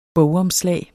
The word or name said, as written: bogomslag